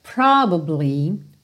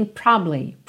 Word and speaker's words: probably: Nessa segunda frase, a gente tem a palavra probably, probably, provavelmente, só que se você procurar a pronúncia dessa palavra no dicionário, você tem probably, probably. Você percebe que você passa nesse b, b, essa sílaba do meio muito rapidamente.